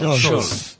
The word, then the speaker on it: Solz: ¿Solz? ¿Cómo se pronuncia? – Solz. Un apellido alemán.